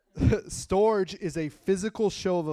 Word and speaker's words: storge: S-T-O-R-G-E. Storge. S-T-O-R-G-E. I thought it was like storage or storage. There's my English language trying to do it. But it's storge. So it's like S-T-O-R-E-G-A-Y is how you pronounce it. Storge. But it's spelled S-T-O-R-G-E.